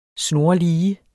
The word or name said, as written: snorlige